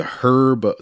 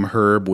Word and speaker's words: herb: I don't know why I try to fix this in my brain by like saying the name herb without the H pronounced.